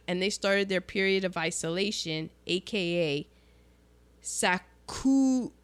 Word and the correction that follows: Sakoku: I butchered that. Sakoku. And that was the government's policy of isolation.